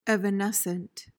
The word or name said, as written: evanescent